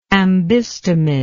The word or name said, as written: ambystomid